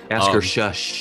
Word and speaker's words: Akershus: I definitely said that wrong. I apologize. – Akershus. – Akershus. – I can say that because I'm a librarian.